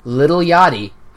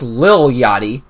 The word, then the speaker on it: Lil Yachty: Lil Yachty, who I believe is a rapper, and I believe I mispronounced that. It's Lil Yachty.